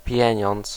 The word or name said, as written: argent